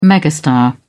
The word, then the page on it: megastar: British English